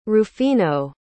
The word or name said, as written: rufino